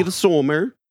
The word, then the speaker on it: Midsommar: Yeah, how do you pronounce that fucking title? – Midsommar.